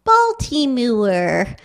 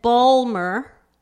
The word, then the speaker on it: Baltimore: They didn't want, and I guess, I surmise, I judge, I that they that Balmer, that the local pronunciation sounded to them what they would have called too Gentile, maybe too Southern, maybe insufficiently elegant, but they were careful and scrupulous to pronounce every single syllable, consonant, vowel, and then to add some, right?